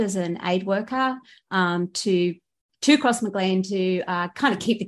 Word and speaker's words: Crossmaglen: the Crossmaglen, I'm going to pronounce it wrong, youth centre. – Crossmaglen, way off.